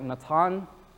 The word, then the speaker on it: Natan: In the Hebrew, his name would be pronounced something like Natan.